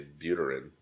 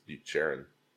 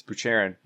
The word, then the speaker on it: Buterin: But as a dumb American, I'd say Buterin, but it's Bucherin. – Yeah, Bucherin. I asked him. It's Bucherin.